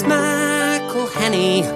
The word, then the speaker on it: McElhenney: It's McElhenney. – McElhenney. – McElhenney. – McElhenney. – If there was doubt, now there won't be any. It's McElhenney.